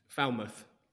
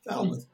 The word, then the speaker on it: Falmouth: How do you say that? – Falmouth. – Falmouth.